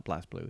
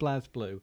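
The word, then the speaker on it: BlazBlue: I'm going to call it Blaze Blue because it is Blaze Blue, not Blaze Blue, isn't it? Even though I haven't got an E. – I think it's Blaz. – Is it Blaz? – I think it's Blaz. I think we've been saying it wrong this entire time.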